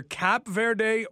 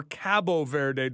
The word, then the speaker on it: Cabo Verde: Spain did not score on either Cap Verde or Cabo Verde, depending on whether you want to use the Portuguese pronunciation.